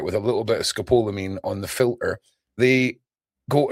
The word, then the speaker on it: scopolamine: But if you harvest certain parts and process them, you make scopolamine. And they show stories of people. – How do you pronounce it? – Scopolamine.